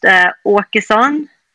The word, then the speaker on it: Åkeson: What is a little circle above the A? What does that do in terms of the sound? – It means that it's pronounced Åkeson.